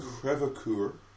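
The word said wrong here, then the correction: Crèvecœur: Crevecoeur. – It's almost like all these names are French. – Well, they are.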